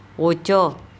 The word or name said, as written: ocO